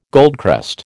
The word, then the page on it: goldcrest: American English